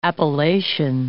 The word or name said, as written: appellation